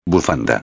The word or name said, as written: bufanda